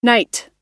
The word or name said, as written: nite